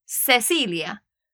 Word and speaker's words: Cecilia: un nombre de una mujer como Cecilia, que empieza con C-I-C-E, en Latinoamérica lo pronunciamos como con una S, so Cecilia. Pero en España dicen Cecilia.